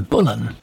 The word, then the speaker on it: Boleyn: Anne Boleyn must rate as one of the most misunderstood figures in British history, and also one of the most mispronounced. She probably would have called herself Anne Bullen.